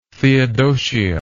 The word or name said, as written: theodosia